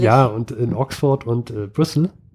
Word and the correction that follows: Bristol: In Bristol.